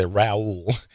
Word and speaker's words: Raul: That's a good way to roll your R's. That's good. – Oh, listen, man, that's I've been proud of that since I was born. I can roll my R's and wiggle my ears. – I have to say my name twice. I say Raul Reyes, and then people kind of go, what?